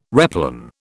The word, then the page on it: replan: British English